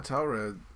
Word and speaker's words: Taured: How would you pronounce that word? – Yeah, Taured.